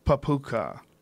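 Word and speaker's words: papakha: this is how you spell it. It's P-A-P-A-K-H-A. Papuka.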